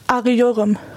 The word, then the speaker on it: arıyorum: Arıyorum.